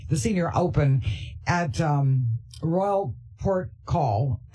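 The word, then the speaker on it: Porthcawl: I am laughing as you are raving about Royal Portcawl.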